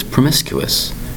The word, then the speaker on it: promiscuous: Today's word is promiscuous. Promiscuous. Four distinct syllables, spelled P R O M I S C U O U S.